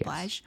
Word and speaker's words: Blige: Blige? Is that how you pronounce it? – Yes. – Blige.